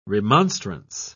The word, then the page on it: remonstrance: British English